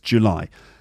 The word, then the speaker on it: July: That's right, the second syllable is stressed, July.